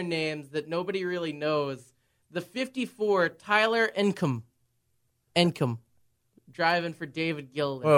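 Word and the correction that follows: Ankrum: Wait, Ancrum or? – Ancrum.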